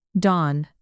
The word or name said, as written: don